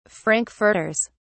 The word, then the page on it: frankfurters: British English